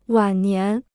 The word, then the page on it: 晚年: wǎn nián